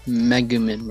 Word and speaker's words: Megumin: Oh, I'm gonna butcher this pronunciation. Megumin. – Megumin, bro. – Yeah. Megumin. Megumin.